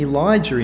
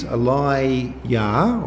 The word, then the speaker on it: Elijah: Because the name Elijah in Hebrew, it's a fusion of two words, El, which is the Hebrew word for God, and Yah, which is the first syllable of the name of God, Yahweh. So he is Eli-Yah or Elijah, which means the Lord is God.